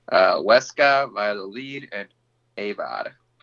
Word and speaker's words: Valladolid: Valladolid.